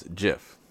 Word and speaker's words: GIF: And this important critical piece of information is Steve Wilhite actually pronounces it as Jif. Who's Steve Wilhite, you're asking me? Who is this guy, and why should we care that he's saying GIF wrong? Well, you should care because he's the one that created it. He is the creator of the graphics interchange format. Yes, he pronounces it like the peanut butter.